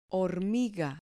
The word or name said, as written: hormiga